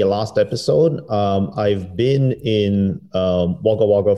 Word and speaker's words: Wagga Wagga: It's fun to say Wagga Wagga. Am I saying it right? – That's right.